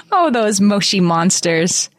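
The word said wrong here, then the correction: Moshi Monsters: They're actually moshy monsters. We're supposed to, we should know better to say it correctly.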